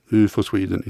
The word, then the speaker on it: UFO Sweden: det ska uttalas så, UFO Sweden, inte UFO Sweden eller något annat.